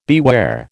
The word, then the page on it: beware: American English